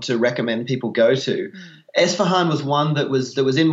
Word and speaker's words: Isfahan: Is it Isfahan? Is that how you pronounce? – Isfahan, yeah, that's right. Isfahan.